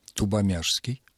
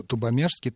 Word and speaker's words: Тубомяжский: Тубомяжский. Коломяги – Коломяжский. Тубомяги – Тубомяжский. Ну, я имею в виду, что Тубомяжский там произносится «ш», а пишется, естественно, буква «ж».